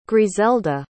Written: grizelda

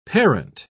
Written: parent

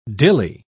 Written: dilly

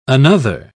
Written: another

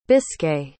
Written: biscay

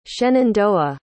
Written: shenandoah

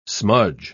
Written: smudge

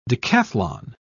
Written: decathlon

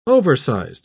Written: oversized

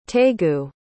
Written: taegu

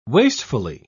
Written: wastefully

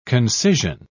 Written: concision

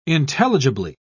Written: intelligibly

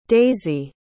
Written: daisie

